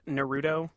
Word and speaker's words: Naruto: Justin, it's pronounced Naruto. – I am so sorry. – Naruto. It is Naruto.